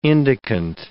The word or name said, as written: indicant